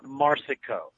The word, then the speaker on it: Marsico: It's Joe Petracca. – Okay. – And it's Ron Marsico. So Marsico.